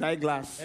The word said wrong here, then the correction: Iglesias: Eyeglass, can he like Anna? – Enrique Iglesias, I said.